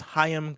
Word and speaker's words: Chaim: DJ Starburst writes in to say, Gartenberg's name is pronounced like Chaim. It's Chaim Gartenberg from The Verge.